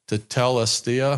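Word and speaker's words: tetelestai: So Jesus did on, when he said it is finished, it's pronounced tetelestia, I think.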